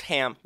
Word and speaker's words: Haim: It's also, it's pronounced ham.